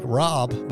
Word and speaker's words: Raub: Check out Kevin, Rob, are you R-A-U-B, Rob. Sounds like Rob, but it's spelt like Rob.